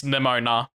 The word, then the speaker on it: Nemona: Nemana. – Nemana and... – Nemona. Oh yeah, it's Nemona, not Nemana.